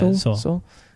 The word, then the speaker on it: Seoul: Is that how you pronounce that city? – Yeah, Seoul.